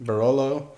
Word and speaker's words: Barolo: It's in the same like region where they have Barolo. Is that how you pronounce it? Mm-hmm. Barolo.